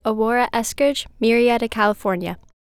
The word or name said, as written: Aurora Eskridge